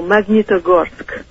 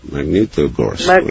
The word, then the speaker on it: Magnitogorsk: Please pronounce for me the name of the town where you were born. – Oh, Magnitogorsk. – Magnitogorsk. – Magnitogorsk. No, it's very simple.